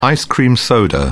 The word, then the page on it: ice-cream soda: British English